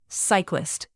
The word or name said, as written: cyclist